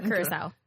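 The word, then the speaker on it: Curaçao: Cassau or curacao? – Curacao. – Thank you. – Did I say it wrong? – Yes.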